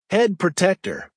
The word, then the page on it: head protector: American English